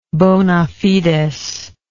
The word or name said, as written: bonafides